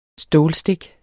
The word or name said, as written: stålstik